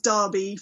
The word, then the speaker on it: Derby: It's actually pronounced Derby for the UK. I guess, like, how you say the name kind of thing.